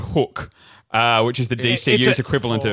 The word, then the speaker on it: Hooq: H-O-O-Q. – But it's pronounced Hook.